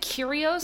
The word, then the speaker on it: Kyrgios: Kyrgios? – Yeah.